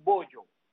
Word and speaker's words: Bojo: Mide 5'9", y ahora, por su personalidad, le dicen un montón de apodos o de nombretes, le dicen Bollo. Bollo, yo sé que suena feo, pero bollo es B-O-J-O, o sea, bojo, bollo.